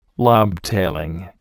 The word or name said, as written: lobtailing